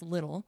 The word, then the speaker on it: Liddell: Now, the girl's last name is Little, spelled L-I-D-D-E-L-L, but it's pronounced Little.